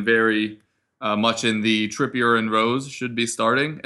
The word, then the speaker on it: Trippier: Son scored twice in three minutes uh, to take a 2 1 lead after Kyrian Tripper, Trippier? Tri- ah, man, pronunciation's not my strong suit today. Uh, but Kieran Trippier conceded a penalty uh, in the first 10 minutes of that match, which, which was not something that you wanted to see.